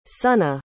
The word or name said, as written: sonnagh